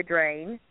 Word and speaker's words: Drane: My name on Facebook is Victoria Drain. My last name is spelled D-R-A-N-E.